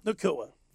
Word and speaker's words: Nacua: Are they going to have to worry about that with Cooper Kupp and Puka Nacua? Did I say that right? – Nacua.